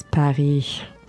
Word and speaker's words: Paris: À Paris. – First of all, Paris is Paris. – All right, yeah. Don't ever say the S at the end.